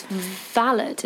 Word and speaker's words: valet: But his uncle, who was at Cambridge, got him a job as a valet. And he actually started working as a valet, or valet. I don't know how you pronounce it. – I think it's valet, isn't it? – It's valet.